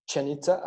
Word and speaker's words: Chichen Itza: Chichen Itza.